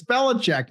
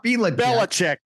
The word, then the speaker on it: Belichick: It's Belichick, not Belichick.